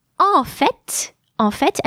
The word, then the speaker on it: en fait: En fait, and I also pronounce the final T, means in fact, or actually.